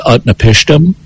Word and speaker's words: Utnapishtim: He's got a four-syllable name. His name is Utnapishtim.